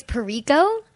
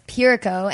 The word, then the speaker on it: Pirico: And when people don't know how to pronounce it, because they say, they think it's Pirico, but it's not, it's Pirico. And I use Unico as an example. It's like the same stress in the first syllable as Unico, Pirico.